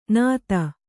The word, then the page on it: ನತಿ: kannada